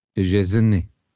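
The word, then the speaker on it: Szczęsny: ¿Cómo se pronuncia Chesney entonces? – Me decían que esa SZ, la primera tiene que ser como shh, y luego era como Chesney. Y acaba como un E, no en Y.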